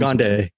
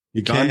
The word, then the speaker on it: Gigandet: Gigandet. His name is, yeah. Gigandet. Cam Gigandet. That's how you pronounce it.